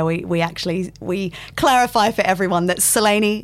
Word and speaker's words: Selene: Because it's pronounced differently in Greek. – Well, I don't know that I'm going to pronounce it perfectly, but it would be Selene. – Selene. – But with a bit of a UK and Oz twang, we say Selene.